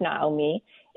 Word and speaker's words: Naomi: So as you said, we're talking about Naomi today, which I'm just going to say her name Naomi because we're just so used to saying it that way. But it's pronounced Naomi in the Hebrew.